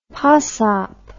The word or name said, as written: pasop